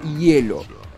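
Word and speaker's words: Yello: Hielo sería con Y y doble L, pero se pronuncia Hielo.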